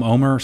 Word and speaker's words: Omar: Why do they say it's spelled Omar, but they call him Omer?